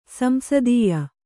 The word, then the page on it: ಸಂಸ್ತೂಯ: samstūya